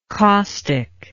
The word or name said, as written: causticness